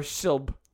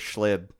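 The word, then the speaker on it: Schilb: Well, it's S-C-H-I-L-B. – Yeah. – Shilp. – So it's either Skilb or Shilp. – Yep, most people mispronounce it as Shlib.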